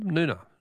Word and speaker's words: Noonga: Noonga is pronounced with a silent G, is it? Oh, thanks, Barb. Noona. Noona. Okay.